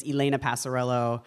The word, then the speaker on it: Passarello: But I think maybe Laura heard me saying my own name in the announcement thing, and I say my name is Elena Passarello.